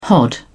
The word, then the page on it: hod: British English